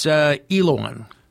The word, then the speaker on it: Elouan: It's Elowon. It's spelled E-L-O-U-A-N.